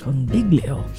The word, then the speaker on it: Congiglio: This is going to be with the Cold Stairs with Corey Congiglio at 7 p.m. I said it right this time. It's a fun name to say. – It is. – Congiglio.